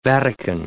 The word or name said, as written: Vatican